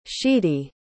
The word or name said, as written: sheedy